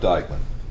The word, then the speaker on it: Diekman: Okay, I'm Jeff Dykeman. – Can you spell that? – D-I-E-K-M-A-N.